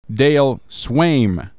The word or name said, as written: Dale Sveum